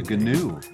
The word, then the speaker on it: gnu: We got Nog, Ogun, and New, G-N-U from Ogun. Oh. – Yeah, a GNU. That's like an animal. – A GNU. – There's no, you don't pronounce the G in GNU.